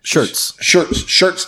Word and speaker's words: Schertz: So Warfighter Tobacco is a tobacco company out of, Schultz, Texas, I think it's. – Shirts, Texas. – Shirts, Texas, which is south of San Antonio. – It's not spelled like shirts, though. – No, it's spelled S-H-U-R-T-S or something. No, S-C-H-E-R-T-Z.